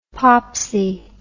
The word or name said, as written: popsie